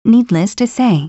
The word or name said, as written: needless to say